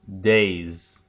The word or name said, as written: days